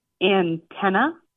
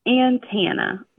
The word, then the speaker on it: antenna: So the way I say it, antenna, A-N-T-E-N, like the number 10, U-H, antenna. And he says it antenna, like an, like an apple, and then tan, like I was just at the beach and I've got a great tan.